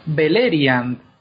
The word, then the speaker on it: Beleriand: Pues Así es como hay que pronunciar también en el turco. Entonces es Beleriand.